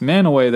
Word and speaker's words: Mantua: I actually lived next to a place spelled the same way, Mantua, in Utah, and it's pronounced Manaway there, but I think it's Mantois or Mantois.